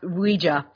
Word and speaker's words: Ouija: they believed that was Ouida in the locket, but Ouija is what came out, and it's actually pronounced Ouija. That's how they said to pronounce it in the original patent.